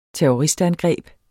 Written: terroristangreb

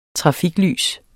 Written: trafiklys

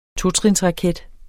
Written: totrinsraket

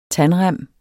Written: tandrem

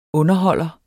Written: underholder

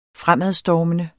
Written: fremadstormende

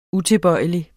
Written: utilbøjelig

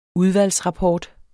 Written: udvalgsrapport

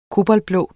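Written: koboltblå

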